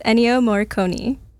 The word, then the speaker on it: Ennio Morricone: It's Ennio Morricone.